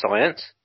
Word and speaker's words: Sainz: His name's pronounced Sainz. – Sainz. – As in, like, as in the science of biology? – No, it's science. I don't know how to explain how to pronounce someone's name.